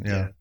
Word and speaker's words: Samhain: No, that's the pronunciation. If you look it up, you know, the real pronunciation of the word Samhain is Samhain. – Samhain, yeah.